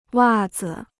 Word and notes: 袜子: wà zi

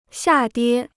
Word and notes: 下跌: xià diē

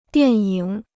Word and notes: 电影: diàn yǐng

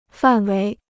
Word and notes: 范围: fàn wéi

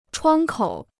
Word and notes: 窗口: chuāng kǒu

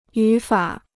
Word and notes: 语法: yǔ fǎ

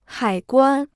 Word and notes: 海关: hǎi guān

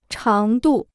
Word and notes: 长度: cháng dù

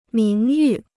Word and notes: 名誉: míng yù

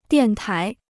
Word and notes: 电台: diàn tái

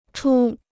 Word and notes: 出: chū

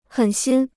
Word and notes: 狠心: hěn xīn

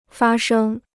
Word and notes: 发生: fā shēng

